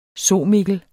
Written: somikkel

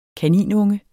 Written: kaninunge

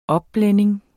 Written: opblænding

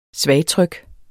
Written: svagtryk

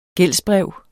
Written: gældsbrev

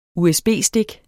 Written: usb-stik

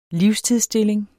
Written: livstidsstilling

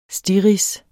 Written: stirrids

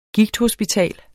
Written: gigthospital

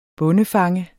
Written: bondefanger

